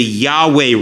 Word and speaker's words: Yahweh: And it's that phrase that gives us that name, Jehovah Rapha, or more correctly, Yahweh Rapha. Jehovah is a mispronunciation of God's name.